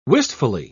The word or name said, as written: wistfully